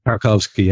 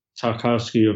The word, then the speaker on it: Tarkowski: So we've gone from looking at Kubali of Napoli to how do you pronounce his surname? – Tarkowski, yeah. – Tarkowski of Burnley.